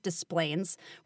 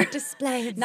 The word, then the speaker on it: Des Plaines: It is pronounced Des Plaines. We're just going to start right there. – Des Plaines. – Not Des Plaines, Des Plaines.